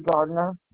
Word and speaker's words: Gardner: Mary Gardner.